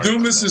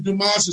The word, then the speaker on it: Dumas: Dumas is fine. It's actually Dumas. It's a French Dumas.